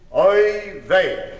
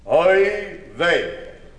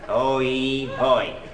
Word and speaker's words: oy vey: The word is oy vey. You there, young man. Let's begin with you. Repeat after me. Oy vey. – Now let me see how I would pronounce that word. Oy hoy.